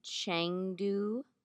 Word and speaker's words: Chengdu: In Chengdu, China, this restaurant.